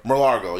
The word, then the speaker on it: Mar-a-Lago: Mar-a-Lago? The shit in fucking Florida. – Mar-a-Lago, yeah.